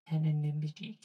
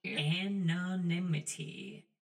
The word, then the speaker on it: anonymity: I can never pronounce this word, forgive me. – Anonymity, cutie. – Anonymity.